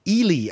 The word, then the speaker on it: Ehle: According to IMDb, it's pronounced E as in triple E, and then Lee. It's pronounced, it's spelled E-H-L-E.